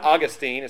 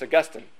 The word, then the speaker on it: Augustine: It's not Augustine, it's Augustine. Or not St. Augustine, it's Augustine. Okay.